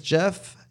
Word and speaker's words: Jeff: It's Jeff, J-E-F-F. Last name is spelled with two L's. It's L-L-U-I-S.